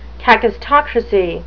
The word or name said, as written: kakistocracy